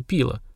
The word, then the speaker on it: пила: Это был общедоступный известный настой вроде земного чая, назывался пила. Ударение на первый слог.